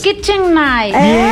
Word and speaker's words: Kitchen Knife: Porque a la vuelta de la esquina, hoy usted se va a poder llevar, gracias a la marca Kitchen Nice. – La marca más reconocida a nivel mundial. – Pronúncialo ahorita. ¿Usted sabe inglés? – Kitchen Knife. – Bien.